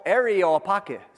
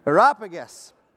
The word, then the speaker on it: Areopagus: Areopagus. Say it again. What he said. Areopagus. From here on known as the A place, because it appears two more times in this. Areopagus.